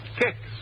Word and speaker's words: Kix: All right, ask your grocer for Kicks tomorrow morning. That's spelled K-I-X. Kicks.